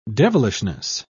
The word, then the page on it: devilishness: British English